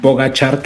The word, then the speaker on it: Pogačar: Pogačar, que ya no sabía que se pronunciaba así, qué bueno que ustedes me enseñaron a pronunciarlo.